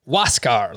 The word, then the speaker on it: Huascar: And just for everyone listening or watching out there, this is the gentleman who spells his name H-U-A-S-C-A-R. It's pronounced Waskar, like just W in front of Oscar, basically.